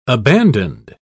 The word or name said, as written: abandoned